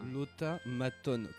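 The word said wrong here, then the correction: Otamatone: Comme ça se prononce, hein. – Ouais. – Oh, ah non, Otamaton, en fait. Il n'y a pas de L.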